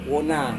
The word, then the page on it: wona: Serewen